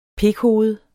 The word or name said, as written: pikhoved